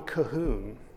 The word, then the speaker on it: Colquhoun: Now, I'm getting these categories from an author named John Calhoun, spelled like Colquhoun, whom I recommend to you.